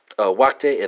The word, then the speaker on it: wachten: Dutch infinitive is spelled W-A-C-H-T-E-N. German infinitive is spelled W-A-R-T-E-N. Spelled respectively, Wachte and Varten.